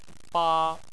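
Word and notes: ba: tone 1

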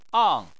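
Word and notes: ang: tone 4

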